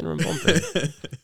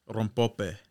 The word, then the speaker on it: rompope: I always pronounce the second M, but there's no second rompompe. M. It's R-O-M-P-O-P-E.